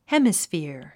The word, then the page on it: hemisphere: hémisfìə r; ヘミスフィャァ